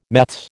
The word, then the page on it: meths: American English